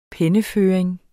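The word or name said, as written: penneføring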